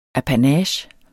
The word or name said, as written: apanage